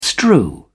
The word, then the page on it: strew: British English